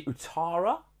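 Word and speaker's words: Ouattara: Even, I think it's actually Uatara, I think is how you pronounce his name.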